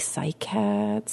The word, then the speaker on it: cycads: I always thought it was like cycads. Cycads? Is that how you say it? – Yeah.